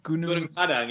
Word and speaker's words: Gunung Padang: Gunung Padang in Indonesia.